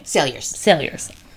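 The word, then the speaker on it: Saliers: How do you say Emily's last name? – Sailors. – I'm glad I asked, because I have never once pronounced it correctly.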